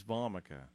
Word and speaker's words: Nux Vomica: It's called Nux Vomica. Let me spell it for you. Two words. The first one is N-U-X, Nux. – N-U-X. – Yeah. And the second one is Vomica. It almost makes you think of the word vomit. That's not what I'm trying to say. But Vomica, V-O-M-I-C-A.